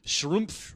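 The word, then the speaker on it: Schlumpf: Schrumpf. Is pronounced like the German word Strumpf.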